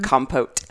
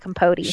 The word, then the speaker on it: compote: Although the name Truman Capote really messed up for me for a while, the pronunciation of compote. I wanted to call it Capote. – Sure. – It's not how you pronounce it, guys.